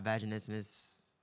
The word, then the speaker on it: vaginismus: Is that how you say it? – Yes. – I have vaginismus. Doesn't sound right to when it comes out of me. – You're saying it right.